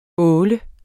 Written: åle

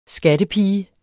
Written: skattepige

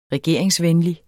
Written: regeringsvenlig

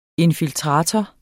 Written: infiltrator

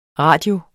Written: RADIOERNE

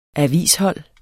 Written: avishold

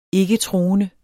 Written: ikketroende

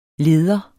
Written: leder

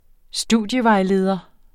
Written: studievejleder

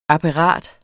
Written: apparat

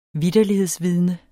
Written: vitterlighedsvidne

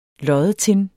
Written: loddetin

